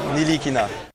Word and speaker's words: Ntilikina: How are we pronouncing it? – Ntilikina is how Commissioner Adam Silver pronounced it. – Isn't the T silent? – Everyone else seemed to pronounce it. – How does the kid himself pronounce it? – Actually, it's Frank Ntilikina. Thank you, guys.